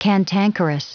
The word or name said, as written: cantankerous